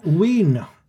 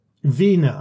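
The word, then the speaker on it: Wien: Wiener. – We were in Wiener. Is that how you're supposed to pronounce it? – Wiener, I think. As in Wiener Schnitzel.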